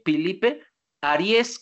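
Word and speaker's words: Ariès: El compilador es Philippe, como Felipe en francés, PH, Philippe Aries como el signo zodiacal, pero con acento en la E.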